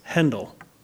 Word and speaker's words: Handel: By the way, the German pronunciation of Handel's name, last name, was Handel, with an umlaut, two little dots over the A. It's very unlike the anglicized version of his name was Handel. Handel is another composer, Jakob Handel, H-A-N-D-L. So, in fact, in England, his name was sometimes spelled H-E-N-D-E-L, pronounced Handel after the German.